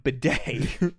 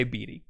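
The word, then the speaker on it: Bede: That's B-E-D-E. I've been calling him Bidet, the toilet thing, because of his crappy attitude. I say BD.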